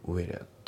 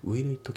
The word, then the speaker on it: will it: あと発音ですけど、 will it のところがリンキングで will it、ね、 will it じゃなくて will it、ね、あと it の t が抜きますね。 a keep it keep じゃなくて a keep だね。だからそこ will keep ですね。だからねここねちょっと聞き取れなかったんじゃないかなっていう感じですね。 will it